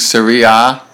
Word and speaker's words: Serie A: Serie A, is that the correct pronunciation of that league? Serie A? – Correct, it is.